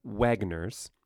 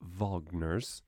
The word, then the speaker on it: Wagner: W-A-G-N-E-R. Not Wagner's, but Wagner's. Ring cycle, which is actually a very long series of operas.